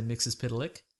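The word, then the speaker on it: Mxyzptlk: I'm just going to, before we start there, does it say Mr. Mixes Piddalick? Because that's how you pronounce that.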